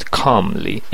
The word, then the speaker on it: calmly: calmly ال تلفظ نمیشه